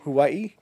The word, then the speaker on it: Hawaii: Hawaii. Am I saying it wrong? – No, you're saying it right, but you just kind of add a "who" in there that I like.